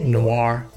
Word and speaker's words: noir: What this movie is, though, is it's also classified as a how do you say noir? – Noir. – Noir. – N-O-I-R-E.